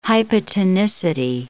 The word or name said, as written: hypotonicity